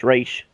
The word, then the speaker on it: Ra's: And, like, you know, there was debate on if it's Ra's or Ra's, and I think Paul Dini came out and said, hey, Denny O'Neill told me it's Ra's, and Denny O'Neill created the character, so I would say however he says it's pronounced is how it should work. – Exactly. That's always what I use the basis of, because I've seen Denny O'Neill in interviews, and he always says it's Ra's, which is means head of the demon.